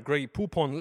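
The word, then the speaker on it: Grey Poupon: And part of the reason could be the fact that just the name of the brand, grey poop-on, lends itself well to rhyming because it rhymes with words such as coupon and futon and neutron and indeed stoop-on.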